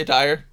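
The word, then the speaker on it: Dier: Eric Deer? – Yeah, Dyer.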